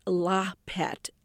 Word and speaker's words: lahpet: Now, I don't know if I'm saying this correctly. It's known as lahpet, L-A-H-P-E-T, although the spelling varies sometimes as well.